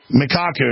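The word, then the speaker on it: Michio Kaku: Leading physics professor, Michiku, how do you pronounce that? I hear his name on the news all the time, but A-K-A-K-U, Mikaku, yeah, we always try to get him on.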